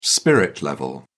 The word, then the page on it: spirit level: British English